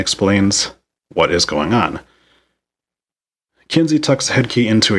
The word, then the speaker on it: Kinsey: Sam calls her Kenzie, which is an odd way to pronounce it, and it was enough for me to put in my notes that he calls her Kenzie instead of Kinsey.